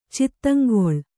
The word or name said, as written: ಚಿತ್ತಂಗೊಳ್